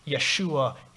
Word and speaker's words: Yeshua: Yahweh, Yahweh who saves, that in the Hebrew is pronounced Yeshua, and in the Greek, Jesus.